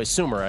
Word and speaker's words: Sumer: Eric Eager, VP at Sumer Sports. By the way, Sumer, it occurs to me, I should probably spell it S-U-M-E-R. That's S-U-M-E-R on Sumer.